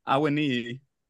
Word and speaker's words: Awoniyi: I've got it this week. Awoniyi. – Oh, bravo. Love to see it.